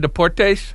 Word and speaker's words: Deportes: ESPN Deportes? – Mm-hmm. – Whatever that is. Did I say that right? – Yes. – I think I did. – Yes, you did.